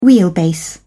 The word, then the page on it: wheelbase: British English